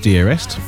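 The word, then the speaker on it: Deerest: Well, they are pronounced Dearest, so that's some, you know, you'll be glad to hear. They have their debut album, self-titled, and that is out right now. – Lovely. And that's D-E-E-R-E-S-T, isn't it? – That is exactly the right spelling.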